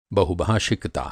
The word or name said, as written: बहुभाषिकता